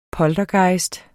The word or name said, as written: poltergejst